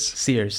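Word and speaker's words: CEERS: So it's called Sears. Am I saying that right? Or Kears? – Sears. Sears.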